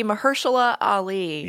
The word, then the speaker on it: Mahershala Ali: It's going to be Mahershala Ali. – Yeah. – That's how you pronounce that. I had to listen to it on YouTube.